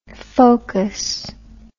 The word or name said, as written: phocus